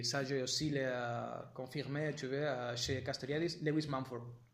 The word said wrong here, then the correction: Lewis Mumford: Oui, la prononciation, c'est toujours un peu difficile. Je l'ai fait à la française pour vous, Mumford, mais non, c'est Lewis Mumford.